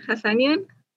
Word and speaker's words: Hasanian: Hasanian? – Yep.